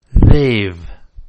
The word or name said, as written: they've